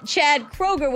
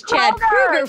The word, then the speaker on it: Kroeger: And then Chad Kroeger, which I found out yesterday was Chad Kroeger from Melissa Chase, and I've been saying it wrong my entire radio career.